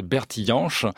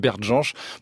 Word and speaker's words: Bert Jansch: La plupart de ses contemporains et de ses concitoyens britanniques ont toujours prononcé Bertie Jansch, mais c'est un nom d'origine allemande et le musicien lui-même insistait pour qu'on prononce Bert Jansch. Il le prononçait lui-même Bert Jansch.